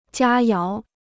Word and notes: 佳肴: jiā yáo

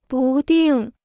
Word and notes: 不定: bù dìng

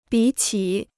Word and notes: 比起: bǐ qǐ

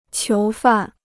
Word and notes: 囚犯: qiú fàn

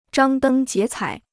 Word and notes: 张灯结彩: zhāng dēng jié cǎi

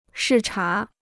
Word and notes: 视察: shì chá

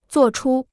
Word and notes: 作出: zuò chū

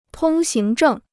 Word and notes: 通行证: tōng xíng zhèng

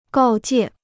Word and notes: 告诫: gào jiè

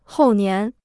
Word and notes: 后年: hòu nián

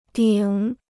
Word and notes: 顶: dǐng

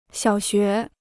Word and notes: 小学: xiǎo xué

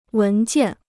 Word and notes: 文件: wén jiàn

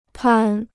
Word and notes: 攀: pān

